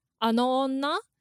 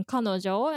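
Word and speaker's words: kanojo (彼女): And then first it was actually pronounced as ano onna. So that's that woman, right? And then it eventually got given a reading kanojo and then became a word of itself.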